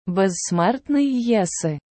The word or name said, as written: безсмертний єси